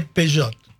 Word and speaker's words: Peugeot: Porque no se dice Peugeot, se dice Peugeot.